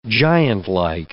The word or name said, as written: giantlike